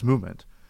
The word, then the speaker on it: MVMT: Movement Watches, spelled MVMT but pronounced Movement, was founded on the belief that style shouldn't break the bank.